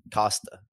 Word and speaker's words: Costa: Costa. Yeah. Sorry. I too much Dan Haley on the pre-show saying it wrong.